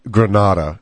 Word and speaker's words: Granada: It's pronounced Granada, and that's where the car came from, I'm pretty sure.